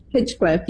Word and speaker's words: Hinchcliffe: Hinchcliffe, yeah.